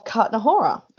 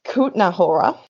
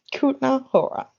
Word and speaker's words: Kutná Hora: So this ossuary is located in the Czech Republic in the suburb of Kutná Hora. Oh, no, sorry, not Kutná. I said it wrong straight off the bat. I'm so sorry to all our Czech listeners. It's Kutná Hora, to say it correctly.